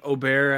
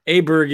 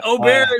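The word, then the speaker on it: Åberg: Yeah, and kudos to you for getting the name right, because I still can't get used to saying Aubert after the Ryder Cup, when, you know, obviously he got a lot of airtime, and Aberg is the English pronunciation to me. So yeah. Aubert is technically correct.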